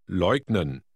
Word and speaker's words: Leugnen: The G is pronounced as in go. Try Word 6. – Leugnen.